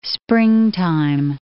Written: springtime

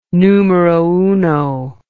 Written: numero uno

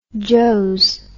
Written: joes

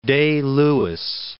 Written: Day-Lewis

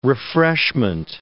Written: refreshment

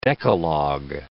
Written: Decalogue

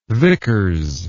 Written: vickers

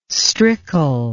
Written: strickle

